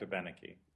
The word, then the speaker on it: Abenaki: Correctly pronounced words, the emphasis, if there's more than four syllables, falls on the third syllable from the end of the word. So that's just the intonational pattern of the language. And it's really clearly illustrated in Abenaki because there are four syllables.